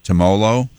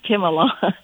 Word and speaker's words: Timolol: Timolol.